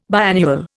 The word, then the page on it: biannual: Indian